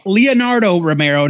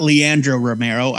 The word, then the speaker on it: Leonardo Romero: It's written by Kelly Thompson with art by Leonardo Romero, not Leandro Romero. I, I've been saying his name wrong probably for years.